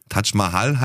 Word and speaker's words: Taj Mahal: Taj Mahal heißt der. – Taj Mahal heißt der, nicht Taj. – Taj Mahal. – Taj. – Was ist mit dir? – Taj Mahal.